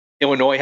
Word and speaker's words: Illinois: Illinois has a silent S, and they're playing in Des Moines, which has two silent S's.